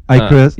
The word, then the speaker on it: Icarus: Icarus? – Yeah.